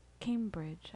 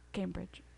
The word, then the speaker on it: Cambridge: And Cambridge. I just said it wrong. Cambridge.